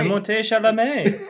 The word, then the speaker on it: Timothée Chalamet: Am I saying that right? – Timothée Chalamet. – That's it.